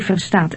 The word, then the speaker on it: verstaat: Hij verstaat Engels. Verstaat. Hij verstaat Engels. – Note the T sound at the end of understands.